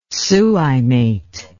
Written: suimate

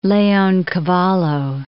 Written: Leoncavallo

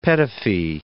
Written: Petofi

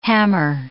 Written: hammer

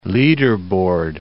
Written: leaderboard

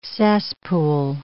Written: cesspool

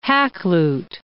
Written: Hakluyt

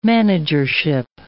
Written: managership

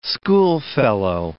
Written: schoolfellow

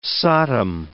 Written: satem

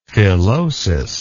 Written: filosus

